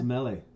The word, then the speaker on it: Semele: Wait, what's say her name again? Semele? – Semele.